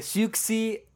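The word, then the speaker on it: Siouxsie: Perché Suxy sarebbe la pronuncia corretta.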